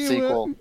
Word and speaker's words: SQL: Sequel, that's how you pronounce it.